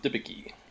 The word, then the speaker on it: Debicki: Elizabeth Debicki.